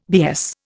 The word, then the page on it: BS: Indian